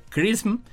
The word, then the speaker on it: XRISM: Un nuevo satélite llamado Misión de Imágenes y Espectroscopía de Rayos X, o el acrónimo XRISM, por sus siglas en inglés y que se pronuncia CRISM, tiene como objetivo separar la luz de alta energía en el equivalente de un arco iris de rayos X.